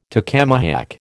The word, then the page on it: tacamahac: American English